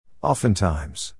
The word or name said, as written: oftentimes